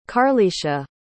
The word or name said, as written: karlesha